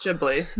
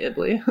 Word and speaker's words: Ghibli: No, it's Ghibli. You could just make it a silent G and just say Ibli.